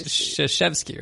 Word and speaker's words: Krzyzewski: Krasuski. – It's Krzyzewski, right? – Yes, it is.